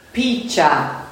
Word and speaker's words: Picha: Picha. – Picha. – Like peach with an A at the end.